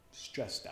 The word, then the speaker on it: stressed: Stressed, stressed. ¿Qué sonido escuchan al final de la palabra stressed? La T, t, t, ¿no? Se lo pronuncia con la T.